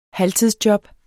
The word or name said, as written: halvtidsjob